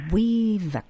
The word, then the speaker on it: Uivik: Weevak. – A weevak. – Oh. Weevak is spelled U-I-V-I-K.